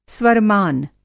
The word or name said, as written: स्वरमान